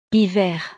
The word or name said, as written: hiver